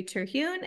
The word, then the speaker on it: Terhune: So my name's Tori Terhune, and it is spelled TORITERHUN as in Nancy E.